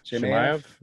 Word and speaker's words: Chimaev: Chimaev.